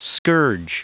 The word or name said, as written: scourge